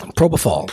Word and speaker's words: propofol: Propofol.